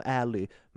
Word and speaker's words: Ali: Eh, nej, men man säger det med amerikanskt uttal, så det låter som Ally, men stavar det Ali.